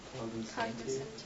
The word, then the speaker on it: cognoscenti: how do you pronounce that? – Cognoscenti. – Cognoscenti? We'll call it cognoscenti.